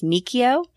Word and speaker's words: Macchio: It is actually pronounced Macchio, but he just lets it slide.